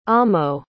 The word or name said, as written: amo